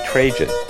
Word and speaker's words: Trajan: Up next will be Trajan. Pronunciation corrected by my son. He's the one with a degree in classical studies.